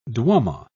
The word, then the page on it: duomo: British English